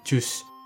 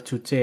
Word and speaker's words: Juche: Saya pernah dengar ya beberapa konten kreator nih salah bacanya dari jus. Bukan ya, bener Juche ya.